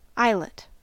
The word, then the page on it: eyelet: American English; /ˈaɪ.lət/